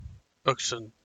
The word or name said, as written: Akshan